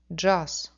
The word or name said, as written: dzhaz